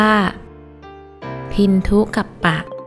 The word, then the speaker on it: พินทุกัปปะ: สวัสดีค่ะพบกับรายการคำวัดสำหรับวันนี้เสนอคำว่าพินทุกับปะ